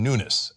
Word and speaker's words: Nunes: Well, we've got more drama and problems with House Intelligence Committee Chairman Devin Nunes. And by the way, it turns out that is how his name is pronounced. He's Portuguese.